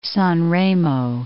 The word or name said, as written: San Remo